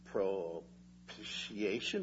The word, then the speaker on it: propitiation: Not only is he our advocate, not only is he the righteous one, but thirdly, he is the propitiation for our sins. And not for ours only, but also for the sins of the whole world. Wow. That's a $75 word. Propitiation.